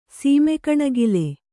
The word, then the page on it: ಸೀಮೆಕಣಗಿಲೆ: sīme kaṇagile